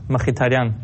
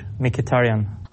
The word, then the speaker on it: Mkhitaryan: In Armenian you pronounce Mkhitaryan, in English Mkhitaryan.